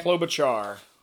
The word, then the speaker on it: Klobuchar: And then they went to Klobu Kloba how do you say her name? – Klobuchar. – Klobuchar. We'll stick with that.